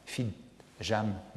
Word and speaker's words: Fitzjames: Mon grand-oncle Fitzjam n'avait rien qui étonnait, car on sait que les Fitzjames proclament volontiers qu'ils sont de grands seigneurs français et ne veulent pas qu'on prononce leur nom à l'anglaise. Souvenez-vous, plus, tard, fit Jam, m'attend.